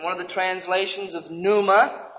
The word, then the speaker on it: pneuma: I'm sure by now that you have learned the truth that the New Testament Greek word for spirit is pneuma, transliterated P-N-E-U-M-A, or pneuma, if you want to pronounce it that way.